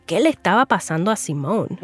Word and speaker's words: Simone: Y sí, estoy pronunciando Simone con una E al final como nombre femenino.